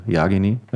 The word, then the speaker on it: Jagini: Äh, also wir sagen alle Jagini dazu, aber eigentlich heißt er Jagini, also die Betonung auf dem A, weil es eben kroatisch ist.